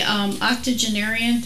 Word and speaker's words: octogenarian: And the octogenarian, did I say that right? – Yes. – Okay.